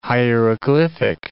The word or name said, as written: hieroglyphic